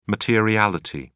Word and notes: materiality: British English

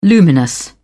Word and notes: luminous: British English

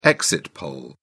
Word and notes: exit poll: British English

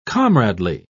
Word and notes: comradely: British English

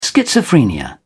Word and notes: schizophrenia: British English

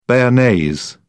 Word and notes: bearnaise: British English

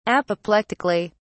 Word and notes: apoplectically: British English

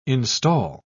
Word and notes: instal: British English

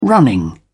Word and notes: running: British English

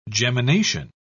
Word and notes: gemination: British English

